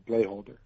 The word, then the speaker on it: Blaeholder: B-L-A-E-H-O-L-D-E-R, Blayholder. – Okay. – Also, George Yulee, that's G-E-O-R-G-E, Yulee.